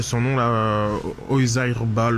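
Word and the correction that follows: Oyarzabal: Oizair Zabal.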